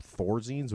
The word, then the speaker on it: Thorazines: And he just like the whole time he's doing it, he'll pop Thorazines, which are like a schizophrenia.